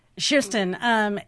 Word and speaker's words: Kirsten: Shirsten, yes, how she pronounces her name.